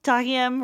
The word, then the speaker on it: Tahiem: probably pronounced Tahim, but Busta Tahiem Rhymes.